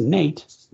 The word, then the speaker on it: Nat: It's spelled N-A-T, and some sources will say it was actually pronounced Nate, but anyway, Nat.